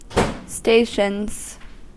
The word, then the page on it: stations: American English